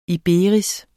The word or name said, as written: iberis